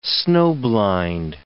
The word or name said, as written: snow-blind